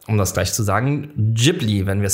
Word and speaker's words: Ghibli: Ghibli ist die japanische Aussprache mit einem stimmhaften J, das ich jetzt auch falsch ausspreche.